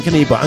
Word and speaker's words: Knyba: I was getting, you know, the pronunciation of the name always throws me because it's spelled K-N-Y-B-A, but it's Damian Kniba.